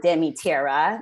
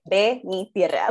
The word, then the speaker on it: De Mi Tierra: I wanted it to be in Spanish and I want everyone to pronounce it in Spanish. It's not De Mi Tierra. No, it's De Mi Tierra.